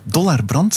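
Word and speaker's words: Dollar Brand: We horen heel stil op de achtergrond een eh, stukje van ehm, Dollarbrand, zeg ik dat goed?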